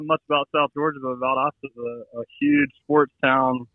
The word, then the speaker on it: Valdosta: Valdosta, Georgia.